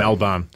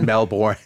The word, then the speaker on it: Melbourne: Did I say Melbourne right? – You did. – I said it right. Oh, there you go. – Melbourne. – Very big. Not Melbourne. – Melbourne. Right. The Australian way.